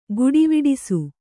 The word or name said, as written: ಗುಡಿವಿಡಿಸು